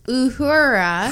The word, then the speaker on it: Uhura: Uhura.